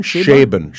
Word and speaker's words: Chabon: Do you say Michael Chabon? – Chabon. Chabon. Chabon. Michael Chabon.